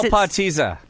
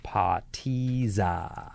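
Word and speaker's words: potica: How do you spell patiza? – P-A-T-I-S-S-A? – Just type in Slovenian strudel. – P-O-T-I-C-A. P-O-T-I-C-A. But it's pronounced patiza.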